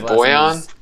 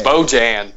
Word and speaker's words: Bojan: Just isn't it Boyan? – Oh, is it really? – Yeah. – That's how you pronounce that J in there? – Yeah. – Ooh, okay. – Bojan.